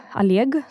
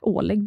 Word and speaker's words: Oleg: Och så min morfar heter Oleg, eller Aleg som det uttalas. Oleg brukar många svenska säga.